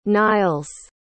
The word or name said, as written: niles